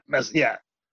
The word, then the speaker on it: Basquiat: Basquiat? – That guy.